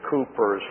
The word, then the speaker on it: Cowper: This past few days, I've been reading again some of the events of William Cooper's life.